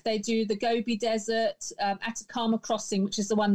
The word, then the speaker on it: Atacama: So if I get the pronunciation right on this, tell us about the Atacama. Is that how you say it? Atacama. – Atacama crossing in Chile in the desert.